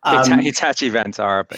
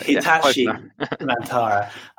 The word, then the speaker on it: Hitachi Vantara: Hitachi Vantara. – Hitachi Vantara.